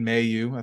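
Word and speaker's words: Mailloux: Jordan Harris, Logan, I know that we've always said it wrong, but I'm going to say Logan Mayu. I think that's the correct pronunciation of it.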